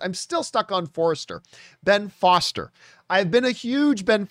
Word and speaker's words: Ben Foster: I don't know if I was thinking of Finding Forrester in the back of my mind, but instead of saying Ben Foster, I kept pronouncing it Ben Forrester.